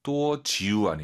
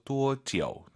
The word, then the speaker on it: 多久: 多지우 아니고 아이유 사이에 오 넣어서 多지오.